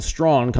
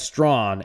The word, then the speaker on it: Strawn: By the way, folks, Chris Cottrell, Winston and Strawn coming up in just a second or two, and I do want to pronounce that Strawn, S-T-R-A-W-N, like brawn, Strawn.